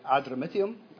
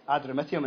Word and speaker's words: Adramyttium: Adramyttium. I got the same pronunciation as Hamish, so we're either both correct. Hopefully, I'm sure we are. Adramyttium, and it's a bit like the Calmac ferry that I was on going to Brodick.